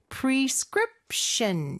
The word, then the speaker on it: prescription: 그리고 마지막에 prescription 여기는 script 요 부분에 강세가 있죠. prescription 에서 이음절의 강세가 있는 거 요거 기억하시면서 우리 큰 목소리로 따라해 볼게요.